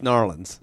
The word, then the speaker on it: New Orleans: I told the patient about it, and she said, "Number one, you got to start saying it correctly. It's New Orleans. – New Orleans. – You say it real quickly."